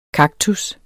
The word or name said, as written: kaktus